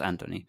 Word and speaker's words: Ant1-10NY: And it's Anthony, or if we the way it's spelt is with like leet speak, so it's like Ant1-10NY, but it's pronounced Anthony, and it's uncommon from Mass Mutation Star Alliance.